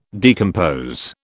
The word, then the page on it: decompose: British English